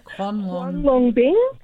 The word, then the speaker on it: Huanglongbing: Huanglongbing.